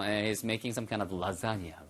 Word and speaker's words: lasagna: lasagna. L-A-S-A-G-N-A에서 발음은요. lasagna처럼 발음돼요.